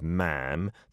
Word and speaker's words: ma'am: And then after that, it is ma'am that rhymes with jam, not swarm, like mom. It's not like that. Or mom. No. It is ma'am in jam.